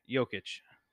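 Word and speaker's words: Jokić: Jonic, is that how you pronounce it? – It was a... What? – How do you actually pronounce it? – Jokic. – Jokic. – Yeah, Jokic.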